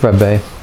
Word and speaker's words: Rebbe: K-O-T-Z-K-B-E-R. Kotzka, and then it's Rebbe, R-E-B-B-E. It's the it's rabbi really, but it's the Hasidic traditions of Eastern Europe slightly alter the pronunciation of rabbi to Rebbe. It's just a sort of dialect thing really. Z-K-B-E-R.